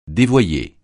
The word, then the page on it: dévoyé: francais